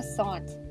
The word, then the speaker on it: Saunt: My name is Hannah Sont, actually. – Oh, so voiceover actually pronounces it right. – Yes, it does. – And how do you spell Sont? – S-A-U-N-T.